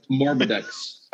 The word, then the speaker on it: Morbidex: But I'm really a big fan of the Morbex Twiceborn. – Yeah. – Oh, he's fun. Big Nurgleman. – Morbidex. Sorry, there's Morbidex. Get his name right.